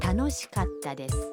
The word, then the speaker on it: 楽しかった: 갔다는 가와 타 사이에 한 박자를 넣어야 합니다. – 그럼 여러분도 따라서 발음해 보세요. – 楽しかったです.